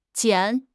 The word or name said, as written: 拣